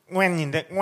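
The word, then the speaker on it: Nguyễn: 발음이 우리나라에 없어서 그런데 그냥 베트남식 발음으론 웬인데 웬. 그러니까 그 NG로 시작하는 발음이에요. NG부터 네. 시작하는 N. 그러니까 영어로 쓰면은 NGYUEN이죠. 웬인데 그게 이제 우리 말로는 그 발음 자체가 없으니까 그 소리를 만들기 위해서 앞에다가 응이라는 걸 넣은 건데 네. 현지에서는 사실 응우엔이라고는 안 읽습니다. 근데 이게 왜 우리 이제 조금 나이 있으신 분들은 이 이름은 들어보셨을 거예요.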